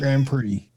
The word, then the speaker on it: Grand Prix: Grand Pre? Pre? Grand? – Grand Prix. – Yeah. I thought it was Pre, but it says pricks.